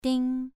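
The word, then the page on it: ding: tone 2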